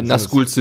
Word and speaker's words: Nazgul: Spricht man das Nazgul aus? Ja, ne? – Ja, ja, ja. ja auf jeden Fall.